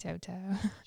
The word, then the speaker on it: Tautou: I thought Audrey Tautou was fantastic in the role. She just... – Tautou.